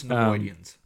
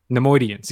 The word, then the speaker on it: Nemoidians: I love the kind of twist of the I'm sorry, I'm gonna butcher this name, but the Naomians, or I'm sorry, I butchered that. – I believe it's Nemoidians. – Nemoidians, yes.